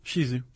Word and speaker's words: Shih Tzu: I always said Shih Tzu. Is it Shizu? – Shizu.